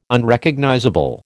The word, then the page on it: Unrecognizable: American English